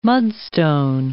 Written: mudstone

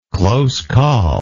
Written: close call

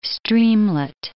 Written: streamlet

